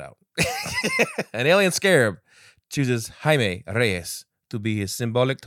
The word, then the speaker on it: Jaime: How do you say his name? – Jaime.